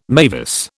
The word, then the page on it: mavis: British English